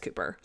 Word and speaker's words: Cowper: his last name is spelled C-O-W-P-E-R, but it's pronounced Cooper.